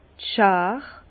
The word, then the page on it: d' Schar: Bas Rhin; Herrlisheim